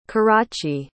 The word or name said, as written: karachi